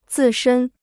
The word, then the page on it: 自身: zì shēn